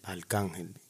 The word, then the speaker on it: Arcángel: Arcángel.